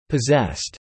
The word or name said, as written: possessed